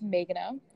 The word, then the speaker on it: Megana: So my name is Megana, spelled like Megan with an A, but pronounced like May the month.